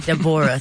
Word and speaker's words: Deborah: Yeah, Deborah, not Deborah, honey. Okay, this is Whitley Gilbert Wayne's child. She was not naming her no Deborah. Deborah, three syllables.